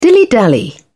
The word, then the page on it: dilly dally: British English